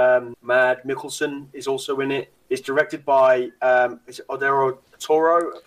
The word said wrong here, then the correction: Guillermo del Toro: Is that how you pronounce his name? – Guillermo del Toro. – Yeah. That's it, Guillermo del Toro.